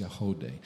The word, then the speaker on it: Yagody: that performs, Y-A-G-O-D-Y, but it's pronounced Yahode.